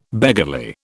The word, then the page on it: beggarly: British English